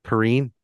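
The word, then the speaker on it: Perine: How do you pronounce that? It's LaMichael. Purney. He's a running back. He went 6 for 41, 6.8 per, Perrine. – Perrine.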